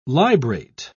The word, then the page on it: librate: British English